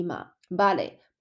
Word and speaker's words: vale: 西班牙文的 B 跟 V 应该是一模一样的发音，应该发 B 的音，所以呃，OK，vale 是 V A L A L E 嘛，vale。